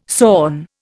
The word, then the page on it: sawn: Indian